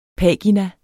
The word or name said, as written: pagina